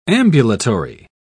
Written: ambulatory

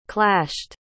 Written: clashed

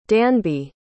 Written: danby

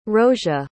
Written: roesia

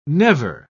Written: never

